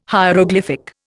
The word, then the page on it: hieroglyphic: Indian